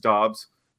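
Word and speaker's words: Doubs: Like, Nevada, they would call him Dobbs every broadcast, and someone finally asked him, how do you pronounce it? And he said, it's Dobbs. So it's similar.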